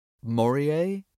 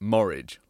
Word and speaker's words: Morije: It's spelled M-O-R-I-J-E. Maurier? Until very recently, we'd been pronouncing it Morridge, like porridge, even though we were almost certain this wasn't a name.